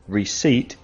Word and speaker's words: receipt: Receipt is what you get when you buy something in a shop. In Spanish, recibo. Can you spell receipt? Spell it now. Receipt. R-E-C-E-I-P-T. Receipt. The letter P is silent.